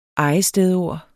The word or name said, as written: ejestedord